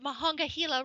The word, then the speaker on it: Monongahela: I actually think I'm getting the Monongahela right, and I believe it is even called by the Pittsburgh natives as the Mon.